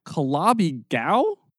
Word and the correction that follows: Calabi-Yau: Yau. Calabi Yau.